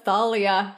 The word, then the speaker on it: Thalia: And that's when we learn about the pronunciation of Talia. – They're going with Thalia. The Thalia truthers have won, which is not us.